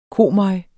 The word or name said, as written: komøg